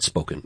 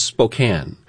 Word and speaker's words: Spokane: And I know it's not Spoken. It's Spokane. And the people who are from here know that.